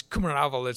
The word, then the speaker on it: Kumaravel: It's Kumaravel.